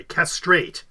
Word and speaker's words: castrate: They say that castrate, collate, cremate, curate, dictate, dilate, donate, fixate, frustrate are second syllable stress. However, others that are on there like conflate, create, equate, elate, inflate, they're the same, first syllable stress.